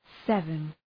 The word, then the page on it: seven: ‘sevən